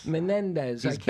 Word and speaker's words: Menendez: But talking about the Senate, we have this guy, how do you say his name, Menendez? – Menendez.